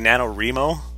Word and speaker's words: NaNoWriMo: So you give me guff for mephites, and then you say NaNoWriMo? – Yeah. – How do you say it? – NaNoWriMo. – NaNoWriMo. – It's right. – Right. – Right. – Mm. – It's not National Novel Reaming Month.